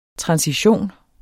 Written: transition

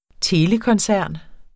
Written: telekoncern

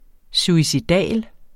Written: suicidal

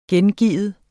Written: gengive

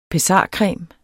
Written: pessarcreme

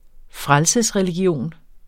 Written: Frelsesreligion

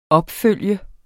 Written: opfølge

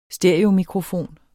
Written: stereomikrofon

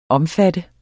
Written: omfatte

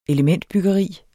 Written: elementbyggeri

